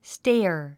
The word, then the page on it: stare: stéə r; ステア